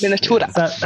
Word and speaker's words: Miniaturas: Am I saying that right? Miniaturas. – Miniaturas. – That's like Spanish for miniatures then, is it? – Yes. Miniaturas. Yeah. Nice and easy.